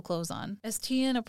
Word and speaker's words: Tiaan: And so this is when Tian Elliard, and this is different than Tian's. It's spelled T-I-A-A-N. Oh. So it's just Tian, I believe.